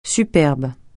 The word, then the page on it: superbe: francais